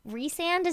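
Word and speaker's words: Rhysand: They, like, do the pronunciation of his name later on the book, which is, I was always right, Rizand. – No, you definitely were right. – But it does look, Rizand's a sexier name. – Yeah. – Rizand is not very sexy.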